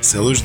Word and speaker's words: селвидж: Ну вот, например, селвидж. Я правильно вообще произношу? Да, селвидж деним.